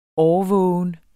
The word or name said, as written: årvågen